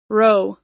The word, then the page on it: row: American English